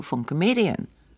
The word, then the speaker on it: Funke Medien: Wollte ich mal sagen, Shoutout to Funke Medien. Habe ich das jetzt richtig gesagt? – Das hast du total richtig gesagt. – Shoutout to Funke Medien.